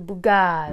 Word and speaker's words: Bugatti: The Bugatti.